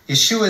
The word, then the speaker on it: Yeshua: Today, you may hear me say Yeshua. Yeshua means King of Kings, Lord of Lords, Prince of Peace. Yeshua is how you say Jesus in Hebrew.